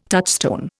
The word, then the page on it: touchstone: Indian